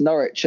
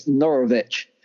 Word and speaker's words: Norwich: I thought I misheard Della pronounce Norwich as Norovich.